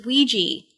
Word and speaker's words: Ouija: You mispronounced that word, though. Like, it's not... It's Ouija. Like, that's how it's spelled.